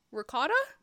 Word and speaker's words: ricotta: Ricotta? There's two syllables that you're not pronouncing. And then she's like, that's how they say it in Italy.